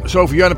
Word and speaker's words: Sophie Janna: En dat is sophiejanna.nl. Zeg ik het goed? Ja, Sophie Janna. Dat is één woord, hè, Sophie Janna. En met een PH, Sophie.